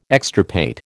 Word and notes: extirpate: American English

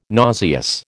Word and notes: nauseous: American English

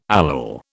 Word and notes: allele: British English

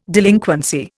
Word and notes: delinquency: Indian

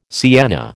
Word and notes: sienna: American English

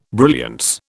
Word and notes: brilliance: British English

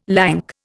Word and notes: lank: Indian

